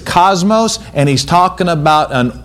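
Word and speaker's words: kosmos: The word world in this verse in the Greek is pronounced cosmos.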